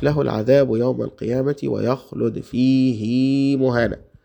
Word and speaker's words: فيه: تقرأ كده في قراءة حفص بمد، وده طبعا خلاف قراءة الهاء اللي هي بعد ساكن بعد الياء، يعني فيه في القرآن الكريم بننطقها كده فيه، إلا دي بس، إلا دي بس في القرآن نقولها فيه هكذا بالمد.